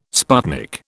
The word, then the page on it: sputnik: British English